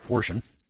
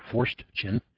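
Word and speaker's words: Forstchen: Forshin. An odd spelling name here. It's an odd name. Here's the way to spell it. It's F-O-R-S-T-C-H-E-N, William R. Forshin, with a foreword by Newt Gingrich.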